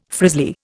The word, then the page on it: frizzly: Indian